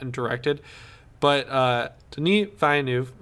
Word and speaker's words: Villeneuve: Is that how you say it? – I think it's Villeneuve.